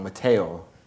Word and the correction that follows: Mathieu: Yes, Mathieu. – Mathieu. – Mathieu.